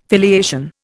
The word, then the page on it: filiation: Indian